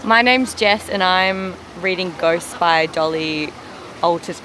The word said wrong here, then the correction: Alderton: Is it? I'm going to start again. How do you say it? – Alterton. – Yeah. Alterton.